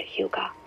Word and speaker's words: hygge: This Danish concept called hygge, yeah, that's how you say it.